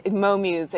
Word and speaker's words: Mo Muse: Is that, am I pronouncing it? Mo Muse? – Yes. – The little one? – Yes, and that, Mo Muse, exactly.